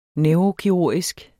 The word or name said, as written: neurokirurgisk